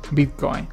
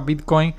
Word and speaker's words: Bitcoin: La palabra Bitcoin lleva tilde Dice, si se adapta al español, bueno, eso lo voy a decir ahorita, se escribe con tilde por ser voz, por ser voz aguda terminada en N, Bitcoin. Esta tweet generó controversias, en parte considerando que muchos en el mundo de la habla hispana a pronunciar la palabra, la palabra Bitcoin lo hacen acentuando la palabra, la primera sílaba.